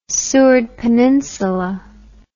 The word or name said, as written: Seward Peninsula